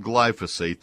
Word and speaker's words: glyphosate: It's it's called glyphosate. That's the active ingredient. It it looks like glyphosate when you look at it the first time, but there's a missing syllable.